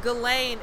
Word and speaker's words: Ghislaine: Her name is Ghislaine. It's spelled Ghislaine.